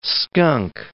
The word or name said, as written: skunk